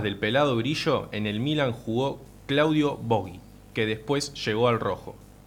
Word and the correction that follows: Borghi: No sé si se pronuncia Boggi o Boggi. – Claudio Borghi.